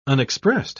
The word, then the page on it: unexpressed: British English